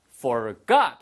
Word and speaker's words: forgot: 예, forgot이 아니고요. forgot 하고 뒤에 액센트가 있습니다.